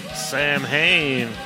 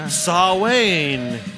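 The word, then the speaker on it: Samhain: This is Sam Hain. Or Sawayne, if you'd like to pronounce it correctly.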